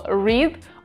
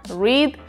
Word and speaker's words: read: Но тут есть один такой коварный паренек, глагол read. Он во всех трех формах пишется одинаково, но при этом читается по-разному. Read, read, read.